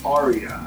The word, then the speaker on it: Aria: So just so you guys know, it is pronounced Aria. I've heard Aria. – Yes. – I've heard Aria. – Yep. – All right, dude, just figure it out. It's Aria. – It's not Ariel.